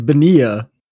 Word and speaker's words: Bonilla: Is that Bonilla? – It's Bonilla. – Oh, I've been saying that wrong my entire life.